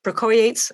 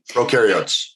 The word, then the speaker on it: prokaryotes: And there was another cell group, those bacteria prokaryotes. I'm not saying it right. – Prokaryotes. – Prokaryotes, right.